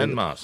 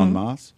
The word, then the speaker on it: en masse: He goes, when they decide to move on masse, is that how you say it? En masse. – Mm-hmm. En masse.